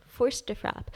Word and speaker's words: force de frappe: And what is the force de frappe? Am I saying that right? – Force de frappe.